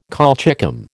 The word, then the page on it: colchicum: American English